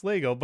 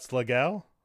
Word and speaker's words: Slagel: It's Slagle, but I said what, Slagel? – Yes, Slagel. Like Brian Slagel.